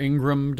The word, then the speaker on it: Ingraham: Laura Ingraham, or Ingram, depending on how you say her name, I say Ingraham.